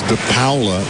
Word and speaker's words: De Paula: You know, it says De Paula, D-E-P-O-W-L-A, in the pronunciation guide. Everybody else says De Paula.